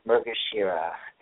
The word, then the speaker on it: Mrigashira: So the fifth nakshatra is called Mrigashira, and it's spelled M-R-I-G-A-S-H-I-R-A.